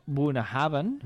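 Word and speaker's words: Bunnahabhain: Bonahaven, pronunciat Bonahaven, uh-huh, això ho ha dit bé, Bonahaven, Bonahaven, sí, o sigui, amb la síl·laba tònica és la primera.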